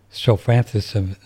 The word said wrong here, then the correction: Strophanthus: How do you pronounce it? – Strophanthus is the plant and strophanthin is the chemical, but it's also called the same substance.